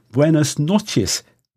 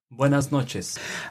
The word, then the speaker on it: buenas noches: Buenas noches. Good evening. How was that? – Excellent. Excellent pronunciation. Buenos dias. Buenas tardes. Buenas noches.